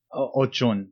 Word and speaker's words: Oshun: You know, like you guys have, I know Oshun is, and I'm probably pronouncing it wrong, Oshun would be like Aphrodite, right? – Ochun, yeah, absolutely.